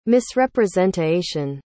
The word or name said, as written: misrepresentaation